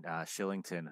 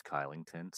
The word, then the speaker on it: Kylington: And the Bruins played the Flames weeks ago, and I want to say I forget, and Shillington, who until that game I didn't know that's how you pronounce his name. – I didn't know either. – Thought it was Kylington, so I apologize to him.